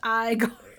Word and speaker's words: Igor: No, it's pronounced Igor. Sorry.